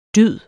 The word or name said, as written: dyd